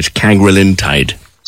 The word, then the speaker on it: cagrilintide: I hope I've got that pronunciation right, cagrilintide.